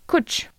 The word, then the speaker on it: cwtch: Il s'écrit C-W-T-C-H. – Attends, mais on le prononce comment ça? – Ça se dit coach.